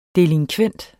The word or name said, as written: delinkvent